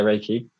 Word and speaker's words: Reiki: I was going to ask you actually, what is Reiki? Is it how you pronounce it? – Yeah, Reiki. It's a meditation technique.